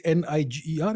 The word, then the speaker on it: Niger: It's called, the spelling is N I G E R. It's called Niger. In, in, That's a French pronunciation.